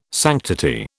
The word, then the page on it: sanctity: British English